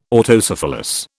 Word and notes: Autocephalous: British English

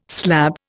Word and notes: slab: Indian

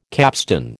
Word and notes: capstan: American English

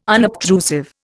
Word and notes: unobtrusive: Indian

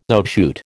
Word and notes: outshoot: American English

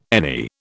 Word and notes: any: British English

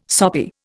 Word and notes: soppy: Indian

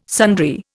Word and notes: sundry: Indian

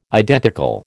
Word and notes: identical: American English

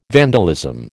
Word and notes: vandalism: American English

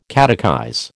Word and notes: catechize: American English

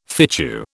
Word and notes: fitchew: British English

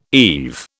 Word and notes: eve: British English

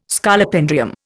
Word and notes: Scolopendrium: Indian

